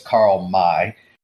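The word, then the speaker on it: Karl May: Well, I say Carl May. I think his last name is actually pronounced Mai. So it's Carl May.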